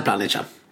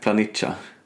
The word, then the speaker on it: Planica: Jag, jag har insett att jag har uttalat det här fel. Man får inte säga Planica. Eh, nej, men jag har ju sagt, Planica. – Jaha. – Och det, det rimmar ju på salsiccia.